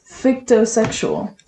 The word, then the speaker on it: fictosexual: is fictosexual. – Fictosexual. – Fictosexual. – Hmm. Fic rhymes with bic, which is a shaving utensil.